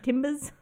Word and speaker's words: Timbs: How much should I pronounce the B in that? Timbs? – Maybe a little less. – Timbs? – Just like that.